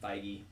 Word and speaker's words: Feige: Is that how you say it? Feige? – Feige.